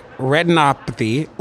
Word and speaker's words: retinopathy: You study diabetic retinopathy. Ooh, I said it right. I said it right, didn't I? – Yes, you did.